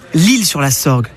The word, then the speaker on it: L'Isle-sur-la-Sorgue: Ah, notez bien qu'on dit l'Isle-sur-la-Sorgue, mais que l'île s'écrit I-S-L-E.